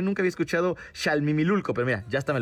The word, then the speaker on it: Xalmimilulco: No es ni siquiera Xalimimulco, es Xalimimulilco. ¿Ok? Si lo estoy pronunciando bien, a ver. Xalmimilulco. Ahora sí, está perfecto.